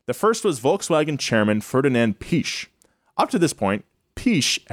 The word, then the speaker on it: Piëch: I'm just going to say Piëch. – The umlaut, you say the, this is real, you say the vowel sound with like a slight like. – Okay, Piëch.